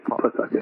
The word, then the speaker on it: Pawtucket: For those non-New England people, for whatever reason, the W is silent in Pawtucket. It's Pawtucket.